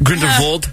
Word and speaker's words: Grindelwald: I think that's how you pronounce it. – Yeah. – That's how Jude Law says it. – Grindelwald, right? – Grindelwald.